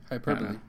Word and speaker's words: hyperbole: Hyperbole. That's how you say it.